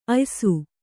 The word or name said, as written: ಆಯಾಸ